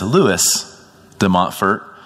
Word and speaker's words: Louis de Montfort: I think that it's completely acceptable and typical in the English language, especially in the Americanized version, to pronounce it as Louis de Montfort. But in the French, he would have been addressed Louis-Marie Grignon de Montfort.